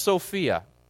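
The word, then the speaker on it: Sophia (σοφία): In Greek, it's pronounced Sophia.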